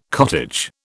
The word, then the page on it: cottage: British English